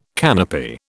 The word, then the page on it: canopy: British English